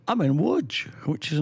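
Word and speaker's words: Łódź: Wodge, well, Lods, pronounced Wodge, or Wodge, right, written Lods, depending on... You know, if you're English, it's an L with a line through it, which is actually called a way in Poland.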